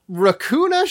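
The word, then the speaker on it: Raccoona: Racuna Sheldon. – Oh, okay. Not Racuna. Just Racuna. – I mean, it's spelled like raccoon with an A at the end.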